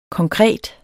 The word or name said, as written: konkret